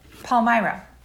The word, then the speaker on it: Palmyra: Palmyra.